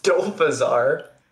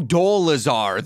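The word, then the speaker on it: Dolezal: Oh, Dolazar. There is no F.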